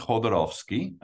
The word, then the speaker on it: Jodorowsky: Yeah, so it's pronounced Jodorowsky, or sometimes he's known as Jodo for short, the great Chilean surrealist filmmaker.